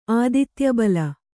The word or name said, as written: ಆದಿತ್ಯಬಲ